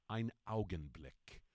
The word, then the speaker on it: Ein Augenblick: The German term Ein Augenblick.